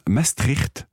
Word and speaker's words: Maastricht: Ça se prononce Maastricht.